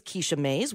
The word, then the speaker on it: Kisha Mays: So today on Beyond Eight Figures, our guest is Kisha Mays.